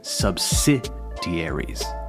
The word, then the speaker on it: subsidiaries: I've noticed that people have a hard time with pronunciation when it comes to words in which the emphasis is placed on the third to last syllable, como si fuesen palabras esdrújulas, subsidiaries.